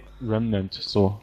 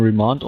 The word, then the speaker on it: Remnant: Remnant, so. Ich dachte, das hätte, spricht man Remand aus, war na gut. Remnant.